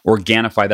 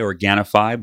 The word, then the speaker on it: Organifi: Organifi, that's O-R-G-A-N-I-F-I. I always spell it because naturally it sounds like it would end in a Y, Organifi, but it's not a Y. It's O-R-G-A-N-I-F-I, Organifi.